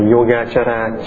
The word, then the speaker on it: Yogacara: Du Già Sư Địa Luận, tiếng Phạn là Yogacara. Cha tức là CA thôi, Yoga CA là cha, tiếng Phạn đọc là Chara.